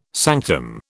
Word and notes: sanctum: British English

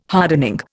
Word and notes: hardening: Indian

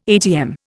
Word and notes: atm: Indian

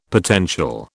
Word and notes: potential: British English

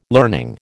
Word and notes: learning: American English

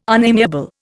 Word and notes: unamiable: Indian

